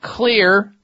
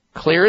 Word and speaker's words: Xlear: And that leads me to reminding you about Xlear nasal spray. Xlear is spelled with an X. The X stands for xylitol.